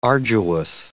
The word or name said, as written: arduous